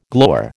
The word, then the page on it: glower: American English